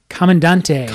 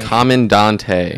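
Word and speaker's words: Comandante: How are we pronouncing this? – Commandante. – Commandante. – That's how I'm pronouncing it.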